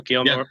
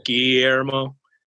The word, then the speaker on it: Guillermo: Guillermo? Guillermo.